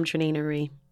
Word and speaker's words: Trenae Nuri: So my name is going to rhyme with 2023. I'm Trinae Nuri for the whole entire year.